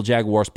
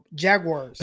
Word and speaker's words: Jaguars: I know I'm saying that wrong. Jaguars. – It's the Jaguars. Jaguars. I've seen the Jaguar car commercials. It's Jaguar. Jaguar. They say it, I think, in the UK sometimes. Jaguars isn't correct. If you want to sound like a gringo, you say Jaguars.